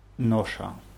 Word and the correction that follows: ножа: У друга нет ножа. Ну, die Betonung war ein bisschen falsch. Ножа auf A.